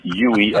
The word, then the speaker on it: Uey: It's spelled U-E-Y at the Buoy.